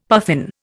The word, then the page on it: puffin: Indian